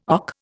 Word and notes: OC: Indian

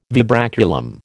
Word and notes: vibraculum: American English

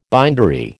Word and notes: bindery: American English

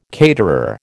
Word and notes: caterer: American English

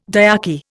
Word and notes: dyarchy: Indian

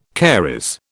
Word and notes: caries: British English

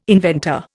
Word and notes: inventor: Indian

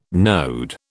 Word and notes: node: British English

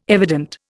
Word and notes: evident: Indian

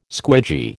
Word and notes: squidgy: American English

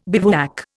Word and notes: bivouac: Indian

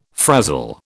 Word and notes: frazzle: British English

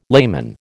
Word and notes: layman: American English

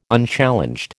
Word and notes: Unchallenged: American English

